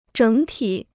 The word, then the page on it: 整体: zhěng tǐ